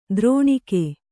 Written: ದ್ರೌಣಿಕ

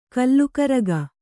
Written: ಕಲಿಕಾರಕ

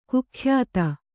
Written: ಕುಖ್ಯಾತ

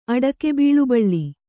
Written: ಅಡಕೆಬೀಳುಬಳ್ಳಿ

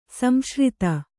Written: ಸಂಸೃತ

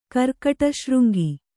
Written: ಕರ್ಕಟಶೃಂಗಿ

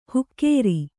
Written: ಹುಕ್ಕೇರಿ

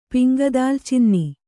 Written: ಪಿಂಗದಾಲ್ಚಿನ್ನಿ

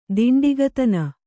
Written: ದಾಂಡಿಗತನ

